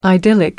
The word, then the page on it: idyllic: American English